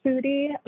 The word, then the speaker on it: Foody: And my last name is pronounced Foody, like the other version of the word foodie that ends in IE.